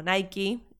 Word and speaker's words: Nike: Nike o Nike, como le dicen en la película, y como nunca le diré yo porque no entiendo por qué se pronuncia Nike.